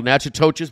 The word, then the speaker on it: Natchitoches: Not it's spelled Natchitoches, but it's pronounced Natchitoches in Louisiana.